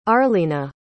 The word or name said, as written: arleana